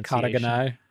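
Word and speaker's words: Shikata ga nai: But do you know, I mean, I think Shishka Gnai, I always mess up the pronunciation. – Shikata Gnai. Shishka Gnai better, though.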